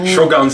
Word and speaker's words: Shogun: A to sice oznámení pokračování Shogun, nebo Shogun? – Shogun, samozřejmě. – Shogun. – Tady s naším místním japanologem zprávujeme, že Shogun je ta správná výslovnost.